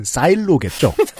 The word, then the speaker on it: 사일로: 콩이라는 게 뭐 거의 뭐 한 병에 한 병 만들려면 콩이 뭐 여덟 대가 들어간다고 하는데 그럼 엄청난 커다란 싸이로 보통 방앗간에서 짜는 건 참기름, 들기름 정도죠. – 네. 네. – 공기름을 지금 짜진 않죠. – 정은정 농축산이 농축산이 어른 발음을 한 저것은 사일로겠죠. 사일로.